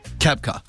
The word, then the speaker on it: Koepka: Koepka.